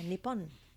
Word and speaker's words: Nippon: Did I say that right? – Yeah, Nippon.